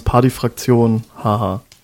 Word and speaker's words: Partyfraktion HH: Wieso, ist das falsch ausgesprochen? – Ja, also aussprechen tut man es Party Fraktion HaHa.